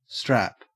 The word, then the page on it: strap: Australian English